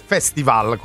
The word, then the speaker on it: festival: Allora, la cosa precisa è festival con l'accento sulla A.